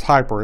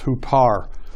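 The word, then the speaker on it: hupar (ὑπέρ): It looks like is hyper. It's not pronounced. It's pronounced hupar.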